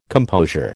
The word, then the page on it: composure: American English